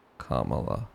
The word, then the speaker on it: Kamala: Camera. Cam. Allah. Kamala. Kamala. – You are helping. – Kama, camera. That's it. That's the amount of work it takes me to change a pronunciation.